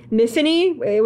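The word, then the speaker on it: Myfanwy: So this is a modern fantasy, not really horror, suspense mystery about a woman named Miffany, which is spelled like Mifonwy, but it's, anyway, pronounced like Tiffany.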